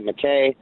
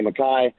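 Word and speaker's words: MacKaye: You know, I liked Ian MacKay, or I don't know how you want to pronounce it. Some people pronounce it McKay, some people say MacKay, but he was the singer of Minor Threat.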